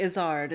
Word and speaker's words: Izzard: Or Eddie Izzard, is as it's pronounced. – Okay. no, no. If I'm doing does it wrong, you got to tell me. With, does not does not rhyme with lizard.